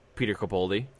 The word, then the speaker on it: Peter Capaldi: But from what I understand, this gentleman, Peter Capaldi, did I pronounce that right? – Yeah.